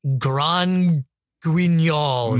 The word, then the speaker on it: Grand Guignol: It's like Grand Guignol.